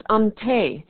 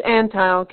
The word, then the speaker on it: Antheil: His name is spelled as if it ought to be pronounced Ante, but George Antheil came from Trenton, New Jersey, the son of a shoe salesman.